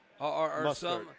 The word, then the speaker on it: Mostert: Well, and you look at it, how you pronounce his last name? Raheem Mostert? – Mostert, 146 yards rushing.